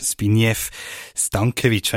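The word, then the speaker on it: Zbigniew Stankevičs: Ich freue mich sehr, dass wir einen hohen Gast haben aus den baltischen Staaten, den Erzbischof von Riga, den Erzbischof Zbigniew Stankevic, wenn ich das hoffentlich richtig ausgesprochen habe. Guten Morgen. – Guten Morgen. Ja, Sie haben das perfekt gemacht.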